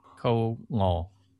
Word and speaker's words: Ngo: Ko Ngo.